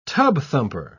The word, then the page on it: tub-thumper: British English